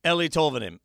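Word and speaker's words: Eeli Tolvanen: How do you pronounce this guy's name? And he said, Willie said Eli Tolvanen. And if Willie says it, I say it. So Eli Tolvanen is what we're going to go with until about 350 of you correct me on social media.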